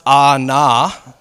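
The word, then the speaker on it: anah: And I think it's interesting too, because when you see how it's pronounced, they break it down in words that like my simple mind can see and understand and read. And it's spelled A-W-N-A-W. And so I'm like, afflicted. Oh, nah. That's in my mind. Oh, nah, dog. Like, you know? But it's actually pronounced ah, nah.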